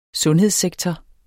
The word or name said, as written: sundhedssektor